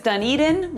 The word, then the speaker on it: Dunedin: It is Dunedin with the E in the middle emphasized.